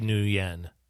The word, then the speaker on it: Nguyen: U-Y-E-N. – I think you should probably apologize for butchering that. – Be Nguyen. – I went to school with a Nguyen. So. – I do apologize to Mr. Nguyen. – Diane Nguyen? – Huh? – Nguyen? – N-G-Y-U-E-N? Nguyen? – Nguyen.